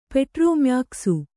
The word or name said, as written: ಪೆಟ್ರೋಮ್ಯಾಕ್ಸು